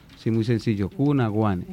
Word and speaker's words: Cuna Guane: En todas las plataformas digitales nos encuentran como Cuna Guane. Cuna, como cuna de bebé, y Guane, de los indios guanes.